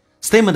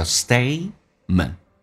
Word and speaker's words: statement: Statement 的第二个 t 是不发音，在美式发音两个辅音在一起，只用发后面一个叫 statement, statement.